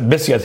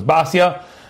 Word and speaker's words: Basya: And some people pronounce it Bisya today, I noticed. When I was a kid, it was always known as Basya, but it seems like people are pronouncing it Bisya.